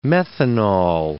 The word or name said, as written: methanol